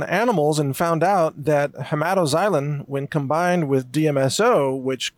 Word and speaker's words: hematoxylin: The second one is a blue dye known as hematoxylin. That's spelled X-Y-L-I-N at the end there. Hematoxylin.